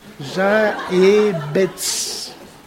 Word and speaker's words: Jaebets: Comme ça se prononce. Jaébetz. J-A-E-B-E-T-S. – J-A-E-B-E-T-S.